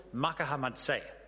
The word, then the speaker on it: Makahamadse: His name is Abel Makahamadse. It took me a while to learn how to say that. The emphasis has to be on the last syllable.